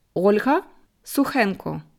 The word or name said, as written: Olga Sukhenko